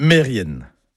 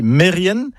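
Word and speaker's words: Merien: Mais si je dis Mérien, oui, vous allez reconnaître le nom de famille, Kermérien, le nom de lieu. Eh ben non, tout ça, c'est faux. C'est Mérienne, Kermérienne. Et au passage, Mérienne, ce sont des fourmis.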